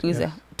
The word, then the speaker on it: Uzzah: Even when David was afraid of God because, was it Uzzah? How do you say his name? – Uzzah died. – Uzzah. Yes.